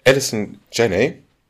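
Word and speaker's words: Allison Janney: Und diese Mutter wird äh, in dem Fall dargestellt von Alison Janney.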